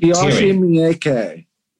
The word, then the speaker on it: Takashi Miike: Takashi Miike.